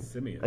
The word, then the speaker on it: Simien: Simien.